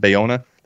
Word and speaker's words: Bayona: Bayona? I'm not pronouncing that correct. I know. Yeah. I'm not. Bayona. That's it.